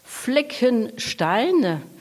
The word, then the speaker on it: Fleckenstein: Fleckenstein,